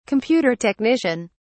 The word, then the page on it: computer technician: British English